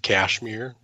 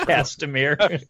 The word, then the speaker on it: Castamere: can't he do the Reigns of Cashmere? I can't even say it. – Castamere.